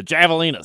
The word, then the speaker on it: Javelinas: I believe it's Spanish for the J is pronounced like an H. And Yeah, I know. I was just trolling you there. The Javelinas.